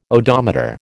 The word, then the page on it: odometer: American English